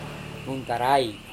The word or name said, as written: nuntarai